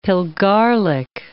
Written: pilgarlic

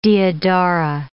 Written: deodara

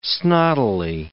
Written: snottily

